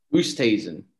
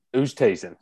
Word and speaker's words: Oosthuizen: I got Louis Oosthuizen. Is that how you pronounce it? – Oosthuizen. – Oosthuizen.